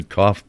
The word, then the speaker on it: cough: I hit the cough button. – You hit the cough button. – And I turned you off. – Okay. – Sorry. – Cough rhymes with off.